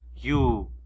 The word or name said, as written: yu